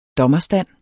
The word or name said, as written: dommerstand